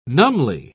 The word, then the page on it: numbly: British English